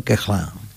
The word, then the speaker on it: Koechlin: Bien, y otro músico francés, maestro de Emile Goué, es Charles Koechlin, así lo pronuncian los franceses, me pareció escuchar. Koechlin sería, ¿no? Bueno, los franceses le dijeron Koechlin.